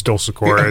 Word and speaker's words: Dulcecor: I mean, I could take you to Italy. My family would be happy. But here it's Dulcecor. Okay. It rolls off the tongue a lot easier.